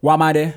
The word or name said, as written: Wamade